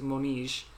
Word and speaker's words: Moniz: And as someone who's half Portuguese, hearing the Colin Firth and Lucia Moniz, or it would be pronounced if you're Portuguese, Moniz,